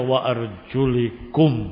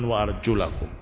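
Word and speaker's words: wa arjulakum (وأرجلكم): Mereka membacanya wa arjulikum, bukan wa arjulakum. Usaplah kepalamu dan kakimu. Jadi suruh diusap kakinya, kata mereka. Salah. Bukan demikian dibaca.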